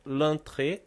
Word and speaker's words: entrée: Escrito, a palavra escrita é muito parecida com o português, mas a, a, a pronúncia é diferente, né? L'entrée, a entrada, é N-T-R-E-E feminino, l'entrée.